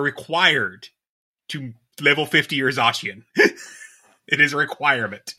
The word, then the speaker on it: Zacian: Zacian and Zamazenta. Did I say that right? Yeah. – Zacian and Zamazenta.